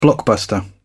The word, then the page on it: blockbuster: British English